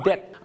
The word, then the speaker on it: debt: ต้องออกเสียงว่า debt I'm in debt ไม่ใช่ debt